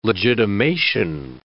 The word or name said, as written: legitimation